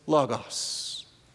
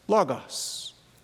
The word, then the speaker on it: logos: The word for word is logos. Logos. Or logos, or logos, or logos, or whatever makes you feel the smartest, all right? However you want to pronounce it.